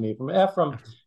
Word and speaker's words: Ephrem: So Ephrem, you could pronounce him Ephrem. Ephraim was he was an important church father in Syria.